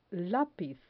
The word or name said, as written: lapiz